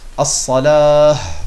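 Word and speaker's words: As-Salah (الصلاة): Und dieses Ta Marbuta, bekannterweise, wenn man stehen bleibt, spricht man es als ein H aus. Das heißt, man sagt As-Salah.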